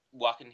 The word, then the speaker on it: Joaquin: the thing with Joaquin, however you say his name, it's Joaquin.